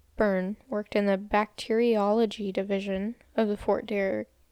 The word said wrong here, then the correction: Fort Detrick: I think I'm pronouncing that wrong. It's Detrick. Fort Detrick. I've been saying Derrick this whole time. I just realized there's a T in it.